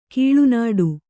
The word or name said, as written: ಕೀಳುನುಡಿ